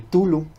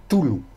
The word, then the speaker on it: Cthulhu: Algunos dicen Cthulhu, es que es como yo como lo he oído que lo dicen, es como el Cthulhu, pero la T no la pronuncian tal, es como Cthulhu.